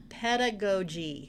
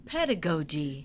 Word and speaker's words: pedagogy: Callie had to correct me. I thought it was pedagogy.